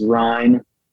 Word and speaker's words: Ryne: So, on Instagram is Ryan. R-Y-N-E.